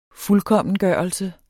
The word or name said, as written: fuldkommengørelse